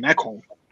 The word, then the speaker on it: Ekholm: Ekholm.